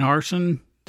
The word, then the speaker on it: Harsin: Uh, what do you think so far, uh, Bryan Harsin? That's correct, right? Is that how you pronounce his last name? – Yes. And absolutely.